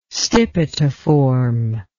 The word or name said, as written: stipitiform